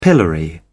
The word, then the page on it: pillory: British English